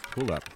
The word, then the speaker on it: Puuluup: To take us out from Estonia to Summerfolk, here's a little taste of Pull Up, that's spelled P-U-U-L-U-U-P, to leave you wanting more.